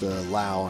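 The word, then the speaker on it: Lowe: It's pronounced Lau, and I think it's spelled like you would pronounce it low.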